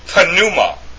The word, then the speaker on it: pneuma: So it's not pneuma in the Greek, it's pneuma.